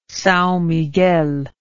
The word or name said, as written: Sao Miguel